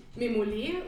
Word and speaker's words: Memole: Genau, also sie heißt tatsächlich Memole, weil es ähm, die Betonung ist auf der ersten Silbe. Mhm. Memole für Medizin mobil lernen.